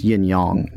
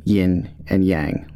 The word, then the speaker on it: yin yang: Yes, it's pronounced yin yang, not yin and yang. Like, that's incorrect.